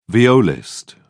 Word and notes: violist: British English

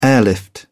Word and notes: airlift: British English

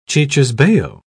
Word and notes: cicisbeo: British English

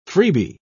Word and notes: freebee: British English

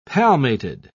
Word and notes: palmated: British English

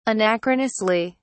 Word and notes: anachronously: British English

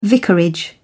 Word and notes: vicarage: British English

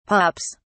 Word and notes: pups: British English

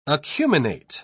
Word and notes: acuminate: British English

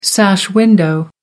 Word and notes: sash window: American English

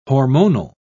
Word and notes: hormonal: British English

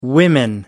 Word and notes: women: British English

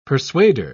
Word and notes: persuader: British English

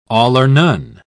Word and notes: all-or-none: British English

